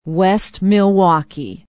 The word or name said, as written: west milwaukee